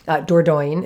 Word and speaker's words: Dordogne: It's a difficult to pronounce name if you're not a native French speaker. Dordogne is how you would say it.